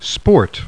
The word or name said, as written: SPORT